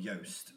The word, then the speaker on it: Joost: And this guy, he's called Yost, which is hard name to pronounce because it's spelled J-O-O-S-T.